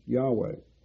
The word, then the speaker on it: Yahweh: When you see the capital LORD or capital GOD in the Old Testament, that's referring to the letters YHWH, which is pronounced Yahweh.